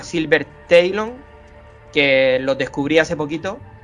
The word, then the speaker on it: Talon: Bueno, pues una banda que se llama Silver Talon, creo que lo he pronunciado bien, Antonio, que la segunda palabra se escribe Talon. – Ah, Talon.